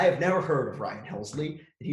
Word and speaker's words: Helsley: That is Ryan Hesley, not to be confused with Ryan Pressly, who is 116 on the list. – Helsley? – Am I saying his name wrong? – I think there's another L.